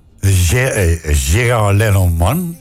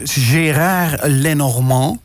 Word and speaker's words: Gérard Lenormand: Gérard Lenormand. Zeg ik dat goed? – Nee, Gérard Lenormand.